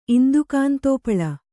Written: ಇಂದುಕಾಂತೋಪಳ

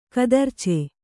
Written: ಕತರ್ಚೆ

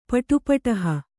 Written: ಪಟುಪಟಹ

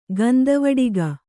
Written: ಗಂಧವಡಿಗ

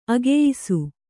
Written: ಅಗೆಯಿಸು

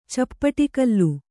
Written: ಚಪ್ಪಡಿಕಲ್ಲು

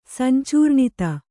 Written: ಸಂಚೂರ್ಣಿತ

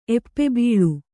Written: ಎಪ್ಪೆಬೀಳು

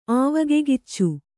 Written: ಆವಗೆಗಿಚ್ಚು

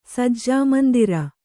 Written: ಸಜ್ಜಾಮಂದಿರ

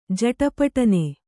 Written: ಜಟಪಟನೆ

